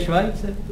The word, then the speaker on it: Schweitzer: No to se vyslovuje Švajce. – Co? – To je Švajce právě.